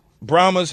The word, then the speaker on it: Brahmas: Brahmas? Brahmas head coach.